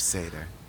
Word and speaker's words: Seder: While we're changing things, let's go back to when Justin said cedar, and let's change it to the correct pronunciation of the word. Cedar is a delicious smelling wood. Seder is like a super delicious religious dinner.